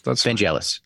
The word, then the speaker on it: Vangelis: Am I saying Vangelis correctly? I feel bad that I don't know. – TV Evangelis? – No, Vangelis. Yeah. That's Vangelis. Right. Vangelis.